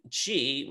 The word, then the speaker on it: Qi: And Qi, which is usually spelled Q-I, but they spell it C-H-I, so we can kind of correctly pronounce, translates literally as air.